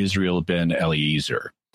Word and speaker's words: Israel ben Eliezer: His actual name was, to pronounce it the English way, would be Israel ben Eliezer.